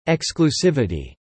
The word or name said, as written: exclusivity